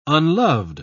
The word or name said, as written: unloved